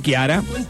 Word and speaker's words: Chiara: Paulo de Río Tercero, está bien pronunciado el nombre en italiano. La CH se pronuncia como la Q. Claro, claro, Chiara. Chiara, sí, señor.